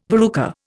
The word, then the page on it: verruca: Indian